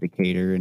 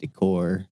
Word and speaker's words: Decatur: I have a street in Vegas called Decatur, and you said Decor.